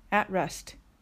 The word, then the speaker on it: at rest: 発音の注意点は at rest です。 at の t は音としては消えてしまうことが多いです。